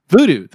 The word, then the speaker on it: Vudu: Vudu? – Vudu. – Vudu, thank you, Vudu.